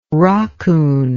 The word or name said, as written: rockoon